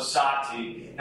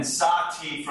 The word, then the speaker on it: SahTea: We did a very early version of SahTea. And by the way, our version is spelled S-A-H-T-E-A, so that the word tea is in there.